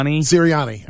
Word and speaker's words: Sirianni: How do you say his name? Sirianni? – Sirianni.